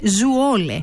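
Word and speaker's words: Zwolle: ότι η πόλη προφέρεται Zuole. Zuole.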